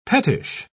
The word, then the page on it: pettish: British English